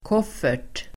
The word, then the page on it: koffert: [k'åf:er_t]